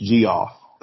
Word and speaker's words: Geoff: So yeah, you wouldn't think, but yeah, like K-E-E-J-E-R-A is kind of how I think of it. – Well, I think G-E-O-F-F is pronounced G-off.